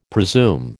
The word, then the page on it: presume: American English